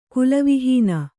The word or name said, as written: ಕುಲವಿಹೀನ